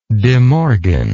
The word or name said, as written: demorgan